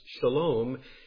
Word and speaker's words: shalom: The peace that is spoken of here is the word pronounced shalom in the Hebrew language.